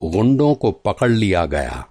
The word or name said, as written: गुंडा